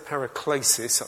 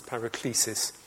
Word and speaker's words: paraklesis: So from here on, I've said paraklesis at the moment, which is the Greek pronunciation. I'm going to drop into it back into English because it's become anglicised as paraklesis.